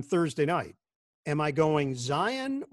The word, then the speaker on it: Zion: He actually pronounces his name Zion, like you said. But in an interview with Jay Billis, when he was still at Duke, he asked him about the pronunciation of his name. And he said, well, it's Zion, but I like when the announcer says Zion. It just makes me sound more powerful.